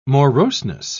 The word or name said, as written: moroseness